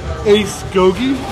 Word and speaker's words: Ace Gogi: I hope I say it correctly. Ace Gogi? – Yes, correct. You got it right.